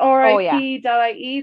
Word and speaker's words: rip.ie: But I think the funny thing about rip.ie is that it rhymes. It's rip.ie.